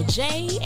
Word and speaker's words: Jaie: This is your girl Jaie, and welcome to the J Spot. And that J is spelled J A I E.